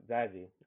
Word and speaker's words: Zazie: Zazie did such a great job. Zazie, I think you pronounced her name correctly.